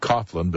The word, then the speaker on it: Coughlin: First hour tonight, Stephen Coughlin, that's spelled C-O-U-G-H-L-I-N like Coughlin, but he pronounces it Coughlin,